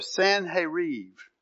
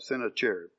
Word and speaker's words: Sennacherib: So after these acts of faithfulness that we saw from Hezekiah, just read about, Sennacherib or Sanherib, that's the correct pronunciation, but I probably won't do that because I have it written down in my Bible one time. I'll probably just call it Sennacherib.